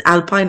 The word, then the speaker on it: Alpine: Isn't, isn't Alpine like a breakfast? – Yes. In fact, this is pronounced Alpine because they're French.